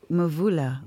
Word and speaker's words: Mvula: I have been a fan of Laura Mulva. Is that how you pronounce? – Mvula.